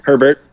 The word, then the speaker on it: Herbert: So I was listening to McAfee interview Hebert. Is that how you say it? The dude out of Oregon, right? – Herbert. Yep. – Herbert.